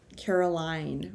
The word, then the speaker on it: Caroline: So here's the thing, actually, is I would like you to pull up a song by Carolyn Rose called Cry. – Okay. – Caroline Rose. – C-A-R-O-L-I-N-E? – Yes. The way you're supposed to pronounce Caroline. – And what was it called again, the song? – Cry, C-R-Y.